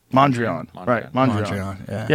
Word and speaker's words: Mondrian: What is it? Mondrian. Yes. Why can't I see that when someone says something wrong, sometimes you go, how do you say it right? Mondrian.